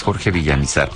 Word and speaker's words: Villamizar: De hecho, creo que se pronuncia, yo lo pronuncié Villamizar, pero creo que se pronuncia Villamizar, es sin acento. Pero siempre le he dicho Villamizar, pero es Jorge Villamizar, por lo menos lo escribe sin acento.